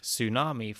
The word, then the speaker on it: tsunami: Think of tsunami from Japanese with the silent T, or pneumonia from ancient Greek with the silent P.